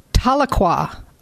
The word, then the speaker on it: Tahlequah: And I learned how to pronounce this yesterday in Oklahoma, and I had been doing it wrong all along. Tahlequah, Oklahoma. I did it right.